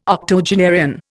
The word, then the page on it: octogenarian: Indian